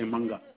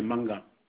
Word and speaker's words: Manga: Alors votre nom de famille, c'est bien Malinga, c'est ça ou non? – Non, le nom de famille, c'est Manga. – Alors, ça s'écrit comment? M-A-N-G-A? – Ouais, comme Mango.